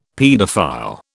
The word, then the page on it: paedophile: British English